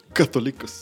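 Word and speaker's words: católicos (καθολικός): Católicos. Católicos. Católicos. Católicos, exactamente. Ese se pronuncia, católicos.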